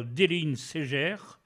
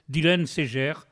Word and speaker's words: Dilenseger: qui est dû au talent de Marie-Pierre Déline Ségère, ou Dylan Ségère, ça s'écrit D-I-L-E-N-S-E-G-E-R, je ne connais que le gascon et je ne prononce ça qu'à la gasconne, Dylan Ségère.